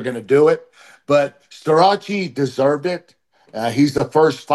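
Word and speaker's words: Starocci: there's another guy named Carter Starace, that's the Italian pronunciation, from Penn State.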